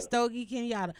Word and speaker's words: Stogie Kenyatta: Stogie Kenyatta.